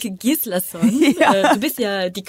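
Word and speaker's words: Rúrik Gíslason: Ich bin sehr dankbar für diese Homepages, auf denen man das nachgucken kann, wie die sich aussprechen. Und ich kann nur sagen, Rurik Gislason. – Genau wie du meintest.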